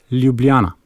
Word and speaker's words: Ljubljana: It's Ljubljana. – Ljubljana? – The first L is with a, I don't know, with a Y, you could say. Like, okay. – Ljubljana.